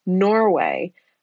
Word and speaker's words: Norwhey: And that is he is a co-owner of the company Norway, and that is spelled N-O-R-W-H-E-Y, because this is a product that is brewed from yogurt whey.